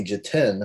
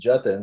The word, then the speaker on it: Jatin: teachers call me Jatin, and when I would try to tell them no, it's pronounced Jatin, they would be like, yeah, Jatin, that's what we said.